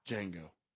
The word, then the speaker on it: Django: But the D is silent. Django.